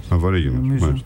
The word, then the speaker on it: Αβορίγινες: Το έχω πάντα απορία. Είναι Αβορίγινες ή Αβορίγινες. – Αβορίγινες. Aboriginals είναι τώρα.